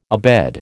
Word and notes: abed: American English

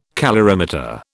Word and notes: calorimeter: British English

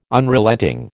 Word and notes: Unrelenting: American English

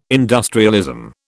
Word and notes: industrialism: British English

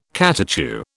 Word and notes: catechu: British English

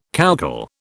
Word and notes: coucal: British English

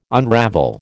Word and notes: unravel: American English

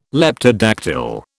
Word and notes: Leptodactyl: British English

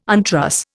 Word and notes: untruss: Indian

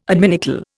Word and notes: adminicle: Indian

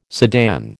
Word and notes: sedan: American English

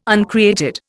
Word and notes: uncreated: Indian

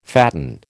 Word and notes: fatten: American English

